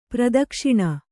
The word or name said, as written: ಪ್ರಾದಕ್ಷಿಣ